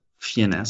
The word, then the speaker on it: Fiennes: Je sais pas comment se prononce son nom, mais il a joué le rôle de Voldemort aussi notamment. Ralph, ouais, Finesse, Finesse. – Fiennes, ouais, bon bah voilà.